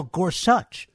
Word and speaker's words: Gorsuch: He keeps calling him Neil Gorsuch, and everybody calls him Neil Gorsuch, and it's Neil Gorsuch. You actually pronounce his name like it's spelled, Gorsuch.